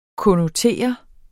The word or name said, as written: konnotere